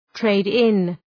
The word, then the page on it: trade-in: ‘treıd,ın